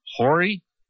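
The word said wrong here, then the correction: Horry: Oree. – Oree.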